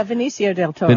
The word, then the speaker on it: Benicio del Toro: Benicio del Toro, did I pronounce his name right? Yeah, no, you're absolutely right. – Yeah, Benicio del Toro.